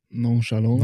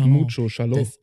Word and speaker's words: nonchalant: Nonchalant, wie man ja heutzutage gerade sagen tut. – Nonchalant. – Nonchalant. – But mucho shallow. – Das heißt nicht ohne Schalo, ja. – Sondern nonchalant.